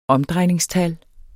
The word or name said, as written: omdrejningstal